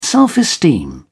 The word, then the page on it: self-esteem: British English